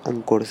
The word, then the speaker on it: Anchor: Si vos tenés el sueño de hacer podcast, instálate Anchor. Se escribe A-N-C-H-O-R. Anchor. Se escribe Anchor, pero se lee Anchor.